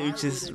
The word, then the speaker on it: Uchis: And it is pronounced Uchis. Uchis.